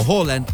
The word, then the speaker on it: Haaland: Sí, después de todo el fenómeno que trajo Erling Haaland, o Haaland, como se pronuncia en su país, pues bueno, el asunto se puso de locura.